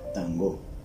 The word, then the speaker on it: tango: Cuando se tocaba el tambor, a ese sonido se le llamaba tango, con ese acento, acento en la O, tango.